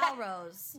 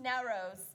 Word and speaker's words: Nowrose: Carolyn sent a name pronunciation guide saying that her last name was like the Persian New Year Nowruz. – So helpful. – And, yeah. Nowrose. – Nowrose.